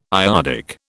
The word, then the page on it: iodic: British English